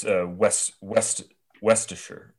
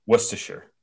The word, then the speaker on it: Worcestershire: Worcester. – No, it's Worcestershire. Worcestershire. – Westshire. – Worcestershire.